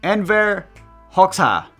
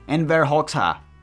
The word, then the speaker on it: Enver Hoxha: Enver Hoxha. There we go. There's your answer, huh? Are you happy with that? Enver Hoxha.